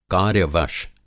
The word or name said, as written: कार्य-वश